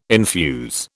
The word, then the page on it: infuse: British English